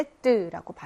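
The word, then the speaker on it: sept: 숫자 7을 나타내는 set는 앞에 있는 빼는 발음이 안 나고요. 이때는 발음이 나죠. 그래서 set라고 발음을 합니다.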